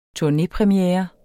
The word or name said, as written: turnépremiere